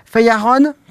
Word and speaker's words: Feijão: En portugais, ça se prononce Feijão, comme on dit. – Feijão. – Voilà. – Peut-être. Après, je ne suis pas portugais. Hein. – Oui, mais bon, oui, c'est F-E-I-J-A-J, donc Feijão.